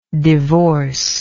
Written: divorcive

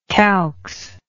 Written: calx

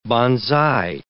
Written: banzai

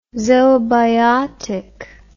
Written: zoobiotic

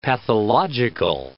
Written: pathological

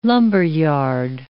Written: lumberyard

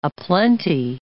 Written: aplenty

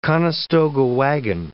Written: Conestoga wagon

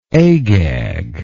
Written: agag

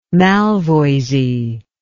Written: malvoisie

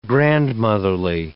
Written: grandmotherly

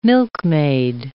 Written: milkmaid

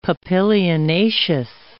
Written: papilionaceous

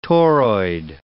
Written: toroid

